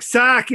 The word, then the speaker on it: sake: Well, I texted Brad and said, well, Bob suggested we could either have sake. Am I pronouncing that right? – Right, sake.